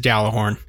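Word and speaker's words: Gjallarhorn: Uh, and as a reminder, the correct pronunciation of Galahorn is Galahorn.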